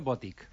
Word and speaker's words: Boutique: Es pronuncia Boutique. – Ah, així ja ho dic. – Sí, s'hauria de pronunciar Boutique perquè no porta accent, però nosaltres ho pronunciem en Boutique també.